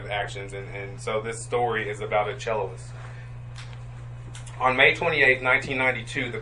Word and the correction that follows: cellist: A cellist and the, am I saying that right, Sandy? Is it cellist? – Cellist.